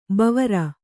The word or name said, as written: ಭವಾರಿ